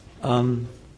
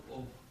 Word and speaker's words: Om: He says, I'm the sound of Om. – Yeah. It's hard to define, actually. Yeah. One place Jiva Goswami says there are three syllables, ah, oo, ah. And they mean Radha, Krishna, and Jiva.